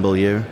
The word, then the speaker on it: Bolieu: It's just my name, Reuben Bolieu.